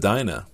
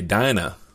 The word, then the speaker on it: Dinah: You just taught me, Bryant, that I can actually pronounce it Dina instead of Dinah.